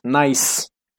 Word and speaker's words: gneiss: Or I don't know how to say the gneiss. Nice.